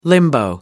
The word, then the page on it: limbo: American English